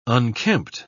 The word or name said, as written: unkempt